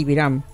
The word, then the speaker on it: Biram: la libertad del diputado Biram, se pronuncia así, Biram. – Sí, Biram. Sí.